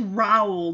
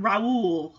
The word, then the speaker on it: Raoul: Okay, his name is Raoul, not Raoul. Okay, I've been pronouncing it wrong.